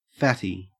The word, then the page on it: fatty: Australian English